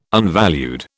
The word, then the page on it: unvalued: British English